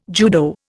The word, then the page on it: judo: Indian